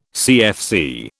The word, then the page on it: CFC: British English